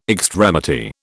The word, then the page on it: extremity: British English